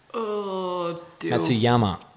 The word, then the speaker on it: Matsuyama: How do you say it? – Well, for a start, I'm pretty sure there's no W in there. Matsuyama. – Yeah. – Not Matsuyama.